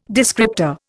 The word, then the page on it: descriptor: Indian